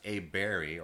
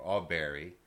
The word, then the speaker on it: Aberg: with eric a Barry which is uh, A B E R G, but it's, it's pronounced A Berry or All Berry.